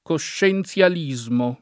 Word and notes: coscienzialismo: lingua italiana

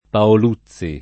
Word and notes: Paoluzzi: lingua italiana

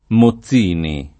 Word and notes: Mozzini: lingua italiana

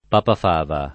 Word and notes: Papafava: lingua italiana

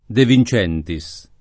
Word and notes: De Vincentis: lingua italiana

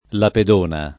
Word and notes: Lapedona: lingua italiana

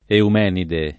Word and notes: eumenide: italiana

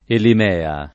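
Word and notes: Elimea: italiano

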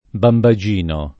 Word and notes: bambagino: lingua italiana

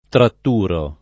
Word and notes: tratturo: lingua italiana

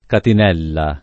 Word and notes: catinella: lingua italiana

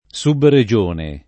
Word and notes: subregione: Italian

